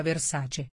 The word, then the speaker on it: Versace: Il gruppo prenderà il nome di Capri Holding Limited, ma attenzione, come ha ricordato tempo fa Donatella sul profilo Instagram della pagina, questo nome si pronuncia Versace.